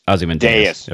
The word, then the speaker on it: Ozymandias: Yeah, Ozymandias.